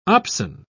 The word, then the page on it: opsin: British English